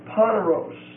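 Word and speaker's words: poneros (πονηρός): P-O-N-E-R-I-A. P-O-N-E-R-I-A. It's a long E. The word means wickedness. Comes from the word poneros, P-O-N-E-R-O-S. It's also a long E, P-O-N-E-R-O-S.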